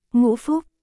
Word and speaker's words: ngũ phúc: U phu là một từ Hán Việt, được đọc là ngũ phúc, trong tiếng Việt, nó có nghĩa là năm điều may mắn, hoặc năm phúc lộc.